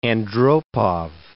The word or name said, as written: Andropov